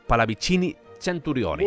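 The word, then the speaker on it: Pallavicini Centurioni: Gracias a estas cartas, en Bolonia entran en contacto con el conde Palavicini Centurione. – Muy bien pronunciado, muy bien.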